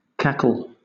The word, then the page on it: cackle: British English